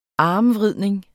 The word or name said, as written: armvridning